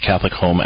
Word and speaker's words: Catholic HOM: It's pronounced Catholic Home, but it's spelled H-O-M.